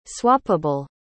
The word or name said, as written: swappable